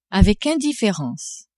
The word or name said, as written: avec indifference